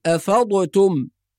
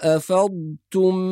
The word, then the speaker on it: أفضتم: أيضا بعض القراء يقلقل الضاد فيقول أفضتم أفضتم، وهذا أيضا خطأ. وبعض الناس يسكت بين الضاد والتاء بحجة إظهار الضاد فيقول أفضتم، كل هذه أخطاء شائعة في التلاوة، والصواب إسكان الضاد ونطق التاء بعدها مباشرة بدون فصل أو سكت أو قلقلة أو إدغام أو غير ذلك من الأخطاء الشائعة.